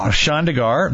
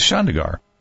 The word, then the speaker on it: Chandigarh: Chandigarh, that's right. And it was interesting because in parentheses it says Chandigarh.